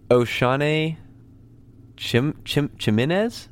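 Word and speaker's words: Oshane Ximines: Let me try to pronounce this. This is a guy that I've seen kind of popping up a little more. Oshane Chimenez? Ximines? – Sounds good. – I like that. The way I pronounced it? – Yeah. No, Yeah, the pick's great, but also the pronunciation is good too.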